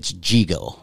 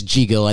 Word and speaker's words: Yigo: So the Y is spelled like a J, so it's Jigo. – Okay. – Or like a, you know, like a J or G, whatever. Yeah, but yeah. It's Jigo, and then you have Y-O-N-A, that's where I'm from.